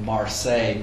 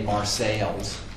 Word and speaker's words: Marseille: And he insisted on calling Marseille, Marseilles, and Lyon, Lyons, because that's how upper-class Brits would pronounce those places.